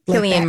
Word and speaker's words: Cillian: But Cillian Murphy, I think you pronounce it. – Cillian Murphy, yeah.